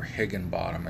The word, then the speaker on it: Higginbotham: Her last name is spelled H-I-G-G-I-N-B-O-T-H-A-M, Higginbotham, or Higginbottom as I say it.